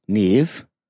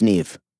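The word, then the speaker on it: Niamh: Niam, pronounced Neve. – Sick. – Do you know about that name? – No. – Okay. It's N-I-M-A-H, pronounced Neve.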